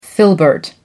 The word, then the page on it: filbert: American English